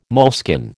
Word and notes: moleskin: American English

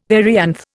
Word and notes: perianth: Indian

